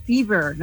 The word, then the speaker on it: fever: Por supuesto que si hemos dicho la cosa empeora, pasamos a fever, ¿no? Fever, o sea, V que se pronuncia... vibra más, ¿no? Que la ve en español.